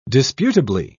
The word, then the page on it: disputably: British English